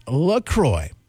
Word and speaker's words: LaCroix: Yeah, it's a cross between La Crosse, Wisconsin, where it's made, and the St. Croix River in western Wisconsin, officially pronounced LaCroix.